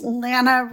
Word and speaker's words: Lana: Hey guys, it's Lana rhymes with Banana W from Bakersfield, California.